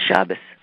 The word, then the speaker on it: Shabbat: American Jews, for instance, sometimes pronounce the more formal Shabbat in a Yiddish-influenced way, and it becomes Shabbos.